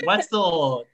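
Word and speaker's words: Woetzl: Wetzel, W-O-E-T-Z-L, a little bit Wetzel.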